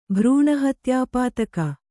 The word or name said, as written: ಭ್ರೂಣಹತ್ಯಾಪಾತಕ